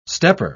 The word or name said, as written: stepper